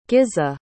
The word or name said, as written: gizeh